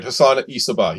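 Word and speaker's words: Hassan-i Sabbah: Of this group was called Hassan Isabah. Is that how you pronounce it? – Yes, that's right. Hassan Isabah.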